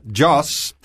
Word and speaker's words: Jos: let me get my pronunciation right, Jos. I'll try and stop saying Joe's, but I'll accept your correction.